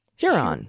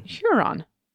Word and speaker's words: Huron: It's Huron. – Huron. More of a long U sound, as in bulge. Oh. – Bugle. – Bugle. Bulge. I'm a little dyslexic today, too. Bugle. Yeah. Huron.